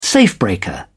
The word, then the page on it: safebreaker: British English